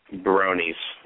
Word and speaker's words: brony: Bronies. Rhymes with ponies.